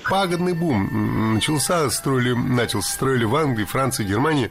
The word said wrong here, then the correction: начался: Много раз прозвучал глагол «начался», но это неверно. Правильный вариант – «начался», только так. Ударение в этом слове падает на возвратную часть «ся».